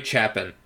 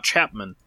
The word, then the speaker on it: Chapin: His name is, let me see, Harry Chapin. – Harry Chap, not Chapman.